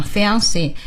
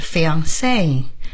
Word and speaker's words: fiancé: 说的是在英语里边很多法语词就原模原样的端来所以呢就像这样 Fiancé 也就这样端来了而且法语的发音重音往往在最后一个要是真正的法语呢是 fiancé 会说这样的但是到英语里边就 fiancé 就变得稍微那个往前靠了一些 OK 语法、